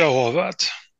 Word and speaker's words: Horvat: Und Ivica Horovat. Ivica Horvat. Ja, du hast du noch den Ivica, den hast du, den hast du drauf.